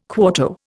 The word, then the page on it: quarto: Indian